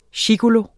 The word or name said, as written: gigolo